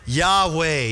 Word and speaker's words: Yahweh: So it's YHWH, and there's two different ways that we are used to pronouncing YHWH. We add our own consonants in. What's the first way we pronounce it? Yahweh. Yeah, we're used to saying Yahweh. That's the way we often like to pronounce it. But there's another way people pronounce it. You know what it is? Yehovah. Or how do we say it in English? Jehovah. Yeah. So you can pronounce it Yahweh or Jehovah.